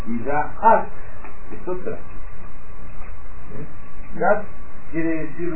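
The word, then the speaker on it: Daat (דעת): Pero ustedes ven que dice Daat, hay dos A, porque debajo de la Dalet hay una rayita, que se acuerdan que los signos vocálicos se agregan a las letras, entonces se pronuncia Daat.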